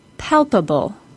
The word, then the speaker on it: palpable: Palpable has the suffix A-B-L-E. I didn't talk about this in any previous syllable stress podcasts, but the A-B-L-E suffix causes the word to be stressed on the third from the last syllable, or for this word, the first syllable, since it is a three-syllable word.